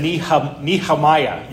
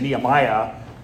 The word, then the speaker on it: Nehemiah: I also like the fact that Joe said Nehemiah. You put an H in it. I think I've heard it pronounced that way as well. So you might have the correct pronunciation. I often just say Nehemiah. I don't put the H in.